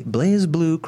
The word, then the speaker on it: BlazBlue: Moving on to the BlazBlue cross tag battle. Am I saying that right? I always get them wrong. – You are saying it right.